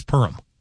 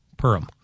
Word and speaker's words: Perham: It's not Perham. Not Perham. Yeah, I would imagine that would be a quick correct in that town. It's Perham. Purim.